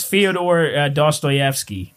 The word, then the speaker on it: Fyodor Dostoevsky: I think it's Fyodor Dostoevsky. – Damn, that was solid, man.